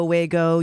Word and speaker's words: WAGO: Rhymes with Lego, but okay, we go, WAGO, you go, whatever.